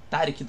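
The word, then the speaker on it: Taric: já que tu dublou o Tarik do League of Legends? – Pois é, eu tinha uma dúvida a respeito dessa pronúncia desse personagem. É Tarik, não é Tarik, é Tarik.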